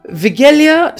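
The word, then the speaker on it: Wigilia: Vigilia supper is a traditional Christmas Eve meal in which Eastern European country? Now, it's spelt with a W, but in brackets it says W is pronounced as V.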